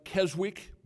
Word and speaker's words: Keswick: It looks like Keswick, but it's Keswick.